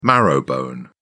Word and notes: marrowbone: British English

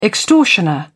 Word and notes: extortioner: British English

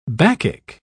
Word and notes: bacchic: British English

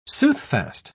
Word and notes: soothfast: British English

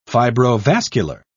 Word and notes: fibrovascular: British English

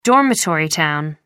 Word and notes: dormitory town: American English

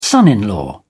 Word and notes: son-in-law: British English

